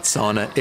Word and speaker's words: sauna: And he corrected you and I and everybody else on the pronunciation of S-A-U-N-A because it is a Finnish invention and Finland is officially not Scandinavian and it's not sauna, it's sauna. – Sauna, like, kind of like November.